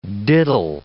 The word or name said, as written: diddle